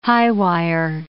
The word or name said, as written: high-wire